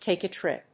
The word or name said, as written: take a trip